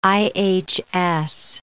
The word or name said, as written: IHS